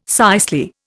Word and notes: cicely: Indian

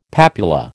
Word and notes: papula: American English

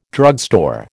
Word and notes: drugstore: American English

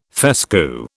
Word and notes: fescue: British English

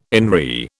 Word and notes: in re: British English

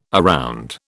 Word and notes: around: British English

